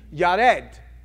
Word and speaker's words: Yared: Yared is how it's pronounced in the Hebrew.